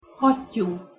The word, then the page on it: hattyu: Hungarian